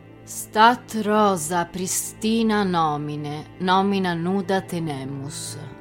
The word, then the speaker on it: Pristina: L'ho corretta. Pristina, non pristina.